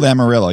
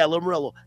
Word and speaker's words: Lamoriello: I can't pronounce his last name. I think it's Lamoriello. You know, in the New York Islanders. – Lou Lamoriello, yeah. – Yeah, Lamoriello.